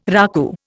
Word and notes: raku: Indian

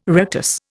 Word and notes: rictus: Indian

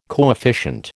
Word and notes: coefficient: American English

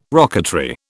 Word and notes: rocketry: British English

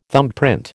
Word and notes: thumbprint: American English

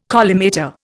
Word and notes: collimator: Indian

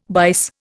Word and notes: bise: Indian

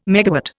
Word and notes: megawatt: Indian

